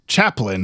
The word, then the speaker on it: Chaplin: Chaplin.